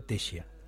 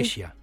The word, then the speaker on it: Tešija: Se escribe Tesija y se pronuncia Tesija.